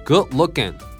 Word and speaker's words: good looking: で D がね L とぶつかって消えるんだ。 good、ね、さらに looking の G もほとんど発音しない感じだね。まあ、この辺を削れるのがやっぱり通な感じかな。 Good looking, good looking.